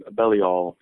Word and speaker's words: Belial: Well, and in the Satanic Bible, the section at the book of Belial, what's the correct way for me to pronounce that name, please? – Belial is the generally accepted.